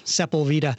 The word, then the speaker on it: Sepulveda: And speaking of California names that are often mispronounced, Sepulveda, you're pronouncing it right, of course.